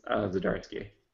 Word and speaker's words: Zdarsky: So I'm sure his Howard the Duck run is great, but yeah, that Chip Zdarsky, is that how you say it? – Yeah, I think it's Zdarsky.